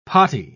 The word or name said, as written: potty